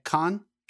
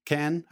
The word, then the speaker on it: Cannes: He has already won Best Director at Cannes, Cannes, however you want to pronounce it, Venice, and Berlin and I bet he will not win Best Director here at the Academy Awards ever.